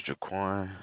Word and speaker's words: Jaquan: My name is Jaquan. How may I help you? – How do you spell it? – J-A-Q-U-A-N. – J-A-Q-U-A-N.